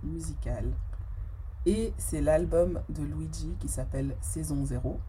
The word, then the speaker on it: Luidji: Donc Luigi, ça s'écrit L-U-I-D-J-I.